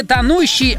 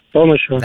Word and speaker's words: тонущий: Чаще всего в причастиях нужно оговориться. Об образованных от глаголов ударение падает на тот же слог, что и в инфинитиве. Но бывает и исключение. Это рубить, терпеть, тянуть и тонуть. В них акцент смещается. По-ентому правильно говорить не тонущий, а как еще раз? – Тонущего.